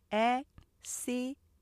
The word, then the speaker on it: accident: 첫 번째 문장에 어, accident 우리가 보통 사음절로 말하기 쉽지만 실제 음절은 삼음절이고요. 제가 잘라서 발음하면 accident 이렇게 삼음절로 되면서 앞에 액센트 되죠. accident 이렇게 하면 강약 리듬 생기시고요.